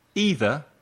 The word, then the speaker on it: either: Now, we practice the difference between any, some, either, and neither. Let's look at either and neither first. There are two possible pronunciations. Listen. Either, either. Neither, neither. Repeat. Either, either. Neither, neither. Los dos son correctos.